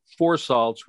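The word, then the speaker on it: phour salts: Well, it's not my website, but it's if you were to look for four salts, which is spelled P-H-O-U-R.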